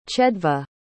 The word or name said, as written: chedva